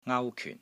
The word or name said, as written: NGAU1 KYUN4